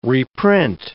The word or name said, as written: reprint